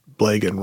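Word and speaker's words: Blegen: Blagan himself said about how to pronounce his name when he was asked that it rhymed with Blagan rhymed with pagan.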